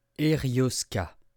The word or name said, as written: erioska